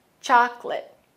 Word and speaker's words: chocolate: Remember, chocolate has only two syllables. That middle O is silent. Chocolate.